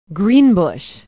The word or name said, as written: greenbush